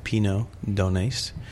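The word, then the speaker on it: Pineau d'Aunis: It's called Pinot Donais. – Right. – And it's spelled, and I'm going to spell it so people can find it, P-I-N-E-A-U, apostrophe, right. – A-U-N-I-S? – Yeah.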